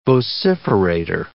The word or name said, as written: vociferator